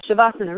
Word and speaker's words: shavasana: She, she fell in love with, I meant to ask you how to pronounce that, uh, shavasana, relaxation, when you do nothing.